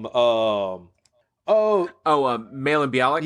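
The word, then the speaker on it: Mayim Bialik: How you pronounce her name? Mayhem, Mayhem Bialik from Malin Bialik. – Yeah, Malin Bialik.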